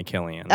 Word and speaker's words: Cillian: It's Irish, right? – So, and it's supposed to be like phonetic and all of it. Mm. So everything's consistent with that. That's I was watching a YouTube video on it recently. – I was too. I got recommended a video about pronouncing Irish, like names and stuff. – Yeah. Anyway, it's definitely Killian.